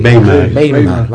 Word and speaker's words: Weimar: Weimar, vale.